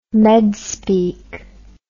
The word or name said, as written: medspeak